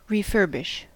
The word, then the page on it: refurbish: American English